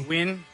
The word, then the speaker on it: Nguyen: Documents say Hugh Nguyen, how do you say that last name? – Nguyen.